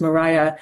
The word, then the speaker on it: Maria: Elizabeth and Maria, or sometimes pronounced Mariah.